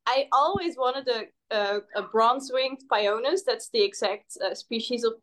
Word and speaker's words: Pionus: In Dutch, we say Pionus. – So Pionus. I was like, I don't know if I'm saying this right. – Me neither. I'm just saying whatever sounds English. But Pionus is what I say in English.